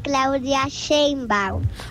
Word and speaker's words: Claudia Sheinbaum: No, es Claudia Sheinbaum.